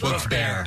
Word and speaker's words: Wilkes-Barre: Wilkes-Barre.